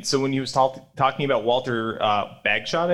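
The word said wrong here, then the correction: Bagehot: I believe is the pronunciation. – Badgett. – Badgett. Okay, right. Badgett.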